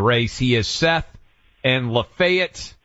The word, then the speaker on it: Lafayette: Yeah, it's Lafayette, like the general from France who helped us win. – The Marquis. Marquis de Lafayette, yes. Yes. – Yeah, Lafayette is who it is.